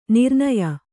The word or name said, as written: ನಿರ್ನಯ